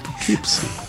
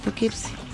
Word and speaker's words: Poughkeepsie: Sí, Poughkeepsie. – Yo le puse el acento en la primera. – Pero sí, pusiste Poughkeepsie.